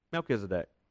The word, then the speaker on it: Melchizedek: That is how you say his name, by the way, though. Melchizedek.